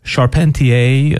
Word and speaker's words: Charpentier: Marc-Antoine Charpentier.